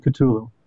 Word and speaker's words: Cthulhu: Can we talk about some of the cult rituals that are done by the Kalula? I think I've mispronounced that. How do you pronounce that? – I'm pronouncing it Cthulhu. – Cthulhu.